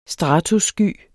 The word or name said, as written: stratussky